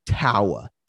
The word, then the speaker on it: Taua: Toa Taua, the pronunciation said it sounds like power. That's an easy way to do it.